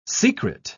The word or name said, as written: secret